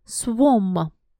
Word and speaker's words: swarm: Number two – swam. Swam. Наш рой или стаи. Там на самом деле пишутся буковки AR в серединке. S-W-A-R-M. Но произносится как swam.